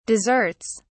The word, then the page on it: desserts: British English